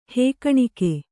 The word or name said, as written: ಹೇಕಣಿಕೆ